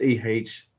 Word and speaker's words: Ehime: It's Ehime.